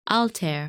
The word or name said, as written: Altair